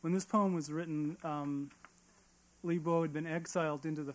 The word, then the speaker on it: Li Bo: The second poem is called Dreaming of Li Bo, who Jessica already introduced to you, oddly enough. Li Po is how most people pronounce it in English.